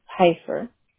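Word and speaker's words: Peiffer: And Cynthia is C-Y-N-T-H-I-A? – Yes. – And how do you spell your last name? – P-E-I-S-S-E-R. Pronounced Pfeiffer. – That's P like Paul, right? – Yes. – Mm-hmm. P-E-I, and is it S or F? – Frank.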